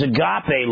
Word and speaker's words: agape: There's agape love. A-G-A-P-E is the way we would spell it.